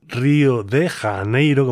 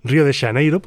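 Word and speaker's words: Rio de Janeiro: Pues volvemos a Río de Janeiro, como se suele pronunciar en castellano. Río de Janeiro, podríamos pronunciarlo perfectamente en gallego. Río de Janeiro, más o menos dicen por allí.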